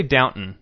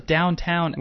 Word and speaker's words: Downton: Well, they say Downton on the show. – I thought it was Downtown. – Well, it's spelled Downtown Abbey, but... – Okay, I don't watch the show. – If you say Downtown Abbey... – People get upset. – People make fun of you.